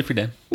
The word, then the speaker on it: Friedan: Yeah, Betty Friedan.